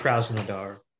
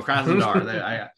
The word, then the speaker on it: Krasnodar: Last season we played, I know you had expertly pronounced their name, but I believe it's Krasnodar from last season. Yeah, Krasnodar. – There you go, Krasnodar. See, sometimes you can rely on me for pronunciation. Other times I got to go to you.